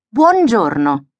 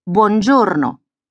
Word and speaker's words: buongiorno: But if the word is longer, they will usually stress the second last one. Signora, buongiorno.